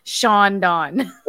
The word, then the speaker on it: Shawn Don: Shawn Don. – Word. Spelled S-H-A-W-N. D-O-N, Shawn Don.